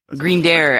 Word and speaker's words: Grindr: Is it Grindr and Grindr actually is how it's pronounced.